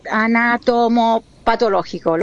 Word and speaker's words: anatomopatológico: Luego, la cosa más importante que vamos a esperar es el examen anatomopatológico.